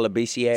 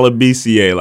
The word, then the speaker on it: Labissière: I think, yeah, I think um, how, how do you pronounce his name? – Skal Labissière. Scal- Skal Labissière. I just wanted to make sure I said that right.